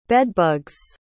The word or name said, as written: bedbugs